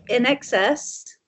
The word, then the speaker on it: INXS: In Excess, remember? What's that song? What is it? – Did you say In Excess? – Yeah.